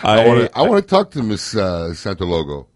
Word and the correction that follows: Sotolongo: Did I say that right? – No. Sotolongo. – Sotolongo.